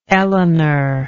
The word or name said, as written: elinor